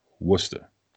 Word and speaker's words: Worcester: Are there R's in the name that you're not saying? Or yes. – Is it that there are no R's? – So it is spelled Worcester. – Worcester. – No, Worcester. All right. Just like the sauce, right? Worcestershire. – Worcestershire. – That's how you say it.